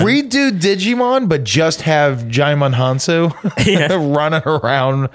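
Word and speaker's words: Djimon: Yeah, it's got Digimon Hansu. That's how I will always say his name. – I know, but I do think it's Jaimon. – Like Jimon. – No, yeah. I think it's Jaimon. Yeah. I think I heard that.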